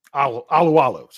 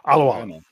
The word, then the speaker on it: Alualu: Alualu. Sorry, I said Lulu. Alualu. Yeah, I said it wrong.